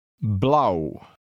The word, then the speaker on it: blauw: Try to read number 19, which rhymes with now. Blauw.